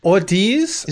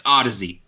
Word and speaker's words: Audeze: Audiz? – Is it Audiz? – It's pronounced Audizy. – Okay. – But it's most people look at it and go, Audiz? – Oh.